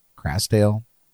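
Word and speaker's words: Krasdale: Did I say that right? Crasdale? – Yeah. Crasdale Foods. – Looks good to me.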